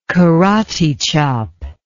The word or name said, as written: karate chop